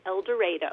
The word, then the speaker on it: El Dorado: Yeah, so actually they, strangely enough, pronounce it El Dorado.